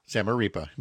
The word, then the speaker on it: Zamarripa: Zamarripa.